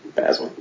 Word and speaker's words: Basil: Well, he got Me Too'd, yeah, well, I guess he did some creepy, you know what I'm talking about, Basil. Oh, yeah. – But, he rhymes with facile.